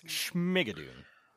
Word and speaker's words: Schmigadoon: but Schmigadoon. – Schmigadoon. – Schmigadoon. Dune or Schmig? – You gotta say it with more Schmig. It's Schmigadoon.